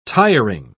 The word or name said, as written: tiring